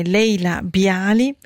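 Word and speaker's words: Laila Biali: Allora, correggo la pronuncia, è Leila Bialy, con questa sua But Not For Me, cantante canadese Leila Bialy, con questo brano tratto dal suo album Your Requests del 2023.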